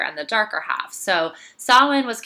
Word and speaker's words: Samhain: So this person is writing in saying, I would love to learn a little more about Samhain, anything you can tell us. So yes, it is pronounced Samhain.